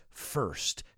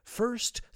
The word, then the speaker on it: first: I said first, careful with the pronunciation there. I've heard students say first. It's not first, it's first. Think of that I as if it were an E. First, through word of mouth.